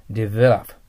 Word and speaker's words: develop: 発音気をつけてください。 develop。 develop ですよ。